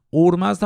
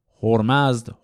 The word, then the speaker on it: اورمزد: اورمزد هم تلفظ دیگر ازش هست هرمزد هرمزد اینا همه تلفظه مختلف همون کلمه